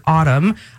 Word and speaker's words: autumn: And actually, right now, you can text the word autumn. I keep reminding people there's an N at the end of that word. – Yes, the silent N.